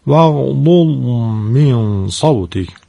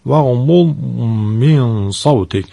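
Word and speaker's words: واغضض من صوتك: با دقت زاد متحرک و زاد ساکن رو تلفظ بفرمایید. وغلول من صوتک، وغلول من صوتک، زاد ساکن تکون نخوره.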